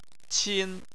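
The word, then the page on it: qin: tone 1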